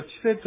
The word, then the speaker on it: チセ: 先ほどですね、アイヌ語ワンポイントレッスンで、私がチセというやつの発音をどうなのかなってちょっとうやむやにしたんですけども、チは低いんですね。チセというふうになります。ですからチセというふうに言うと発音が正しいので、勉強される方はぜひやっていただきたいと思います。